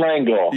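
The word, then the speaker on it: L'Engle: It was chapter one, paragraph one, where you recount a story about Madeline, and I'm probably going to mess up the pronunciation of her last name. – Madeline Langle.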